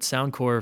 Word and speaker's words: Sound Corps: And by the way, we haven't mentioned it yet, but Sound Corps, for those of you who are listening, is spelled like you would spell Marine Corps or AmeriCorps or Peace Corps, and that's Sound, C-O-R-P-S.